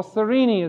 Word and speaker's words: Cyrene: in Cyrene, or Cyrene as some people pronounce it.